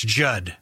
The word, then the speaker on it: Judd: That's Judd with two D's. The second D is silent.